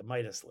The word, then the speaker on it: Midas: There is this list, I think, which Forbes prepares called the Forbes Midas list, if I'm pronouncing that correctly. – Correct, the Midas list.